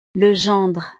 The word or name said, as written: le gendre